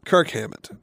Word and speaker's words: Kirk Hammett: Is it Kirk or Kurt Hammett? – Kirk Hammett, with a K.